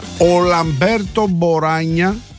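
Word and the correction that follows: Boranga: Μποράγκα, μάλλον, δεν είναι GN, είναι NG, άρα διαβάζεται Μποράγκα.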